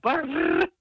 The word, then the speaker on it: bar: Als mijn regering boos is op mij, dan zegt hij tegen mij: jij bent brrr. Dus B-A-R, maar dat moet dan met een rrr worden uitgesproken.